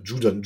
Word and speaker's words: Judon: bei euch Matt Jadun. Oder Judon, Judon heißt er.